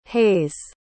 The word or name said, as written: heyse